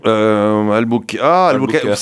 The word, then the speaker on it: Albuquerque: Albuquerque. C'est, alors attendez, c'est peut-être, c'est côte ouest ou côte est ? – C'est Nouveau-Mexique. – Ouais, ben voilà, c'est peut-être Albuquerque, peut-être, façon, ouais.